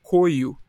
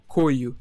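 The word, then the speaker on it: KOYO: Tá abrindo show de várias bandas de peso aí, fazendo turnê com uma galera legal, que é uma banda que chama Coio. Não sei se vocês já ouviram falar. Não. É K-O-Y-O, Coio. Eu acho, acho que é assim que se, pro, que se pronuncia, tá ligado?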